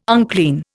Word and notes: unclean: Indian

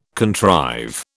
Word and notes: contrive: British English

